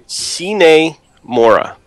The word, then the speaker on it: Sine Mora: What's the correct way to say it again one more time? – Sine Mora.